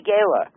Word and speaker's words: Gayla: G-A-Y. – How do you spell it? G-A-Y-L-A. – G-A-Y-L-E? – G-A-Y-L-A. – L-A, Gala.